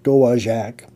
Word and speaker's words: Dowagiac: Schaller first met with families at the Armory in Dowagiac, Michigan, pronounced Dowagiac, from the Potawatomi language,